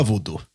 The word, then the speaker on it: voodoo: Uttals man så? Voodoo. – Ja, voodoo.